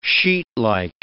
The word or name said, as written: sheetlike